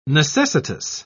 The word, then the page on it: necessitous: British English